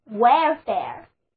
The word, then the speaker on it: welfare: 如果我们在表示意愿的这个词根 W-E-L 之后加上表示费用的 F-A-R-E 单词 Welfare W-E-L-F-A-R-E Welfare 就是我希望被支付的费用那么 welfare 就是我们所获得的福利 welfare 字母组合 A-R-E 发作 air 的音单词就读作 welfare